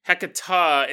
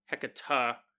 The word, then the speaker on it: Hecate: Hecata is how I heard it previously used. Like the E sound in enough. Hecata.